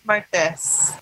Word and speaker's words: Martes: Martes. – Martes.